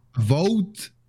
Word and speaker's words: Avowed: czyli e, Awowed. Awo, awowed? Tak, się, tak to się mówi? – Avowed. – Avowed.